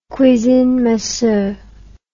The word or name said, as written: cuisine minceur